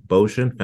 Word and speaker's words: Bocian: We have Shani Bocian, founder and CEO of Allermi, a rhymes with com- ocean.